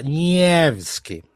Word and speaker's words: Nevsky: E, e a, a, a, pronúncia russa é Nievski.